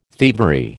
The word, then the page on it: thievery: American English